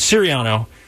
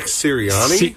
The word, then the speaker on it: Sirianni: Spiriano. Am I saying that right? Sirianni. – Nick Sirianni? – Sirianni, thank you.